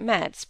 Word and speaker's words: Metz: He never was great in geography, you know, and somehow he thought that Metz, pronounced French fashion, must be on the road to Rome.